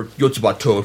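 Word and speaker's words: Yotsuba&!: It's one of the Yotsuba, or Yotsubato, if you're into the Japanese pronunciation of the series.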